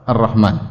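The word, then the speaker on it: Ar-Rahman (الرحمن): Jangan dibaca Alif Lam Ra Ha Mim Nun, ya, jangan. Ya, jangan sampai salah baca Alif Lam Ra Ha Mim Nun salah. Ini bacanya apa? Ar-Rahman, ya.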